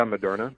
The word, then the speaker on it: Moderna: One is Pfizer, and the other is what, Moderna? Am I saying that right? – Yes. Yeah, Moderna.